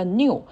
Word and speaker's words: Nu: 一直到现在，这个 Omicron 其实中间还跳过了两个希腊字母，一个是 Nu，叫 New，因为 New 和新的这个是一样的，就是和英文里的那个新的那个单词是一个发音，所以怕担心搞混哈，跳过。